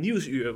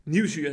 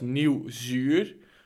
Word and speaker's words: Nieuwsuur: Nieuwsuur wordt vaak, eh, zeg maar, zo uitgesproken als nieuwzuur.